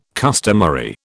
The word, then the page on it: customary: British English